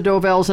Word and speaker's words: Dovells: Del Vos. – Number six. – Not saying it right. – That's all right. You know it's the Dovells, and I'll give it to you for that.